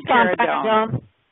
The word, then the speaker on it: Domperidone: That's Domperidone.